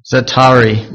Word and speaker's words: Zaatari: Most of the refugees went to the cities first and then overflowed, and then they set up the Zaata refugee camp. Let me just get that pronunciation. Zaatari refugee camp in Jordan.